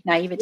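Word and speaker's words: naïveté: Naivete.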